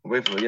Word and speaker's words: UEFA: UEFA, yeah.